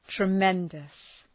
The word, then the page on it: tremendous: trı’mendəs